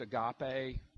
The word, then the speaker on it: agape: It's the word in the Greek that is pronounced agape.